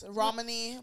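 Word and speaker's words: Romany: Is that how you say his name? Romani? – Yes, Romani Malco.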